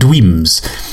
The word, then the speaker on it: Dweams: I played Dweams. That's spelled D-W-E-A-M-S. It's like Dreams, but with an R instead of a, no, with a W instead of an R.